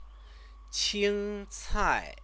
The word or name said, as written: qingcai